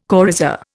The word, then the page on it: coryza: Indian